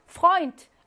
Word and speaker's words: Freund: Freund. 에 오는 오이였잖아. 오이 맞아요.